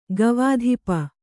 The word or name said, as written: ಗವಾಧಿಪ